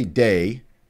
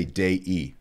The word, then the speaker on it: Dei: That I end, and you don't say Dei, you say Dei. There are two syllables there, Dei, not Dei, Dei, of God.